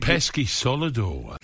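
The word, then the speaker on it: Peschisolido: When former striker Paul Pescalido, he said... – Pesky Solido. – Yes, it finally happened. Alan corrected someone else on their pronunciation.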